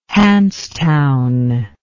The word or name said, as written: Hanse town